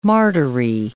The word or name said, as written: martyry